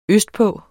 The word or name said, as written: østpå